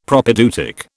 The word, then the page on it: Propaedeutic: British English